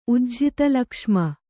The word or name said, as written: ಉಜ್ಝಿತಲಕ್ಷ್ಮ